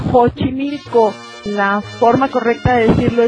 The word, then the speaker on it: Xochimilco: Hemos aprendido que se dice Xochimilco y México. Porque la X depende de la situación.